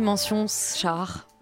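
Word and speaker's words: Mention-Schaar: Et donc j'ai écorché son nom. On dit mention char.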